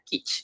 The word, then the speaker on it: kitsch: Yo lo busqué, se pronuncia kitsch.